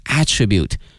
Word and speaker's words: attribute: 当然如果说我们在用这个词的时候呢还要小心 attribute，它还有另外的一个词性呢是名词，作为名词的话呢它的发音是 attribute， attribute、啊、